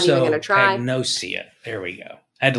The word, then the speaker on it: prosopagnosia: Uh, I think it's propagnasia.